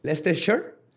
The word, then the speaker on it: Leicestershire: Leicestershire. – Leicestershire.